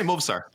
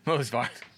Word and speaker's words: Movsar: You say Movsar. – Movsar.